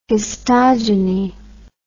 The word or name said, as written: histogeny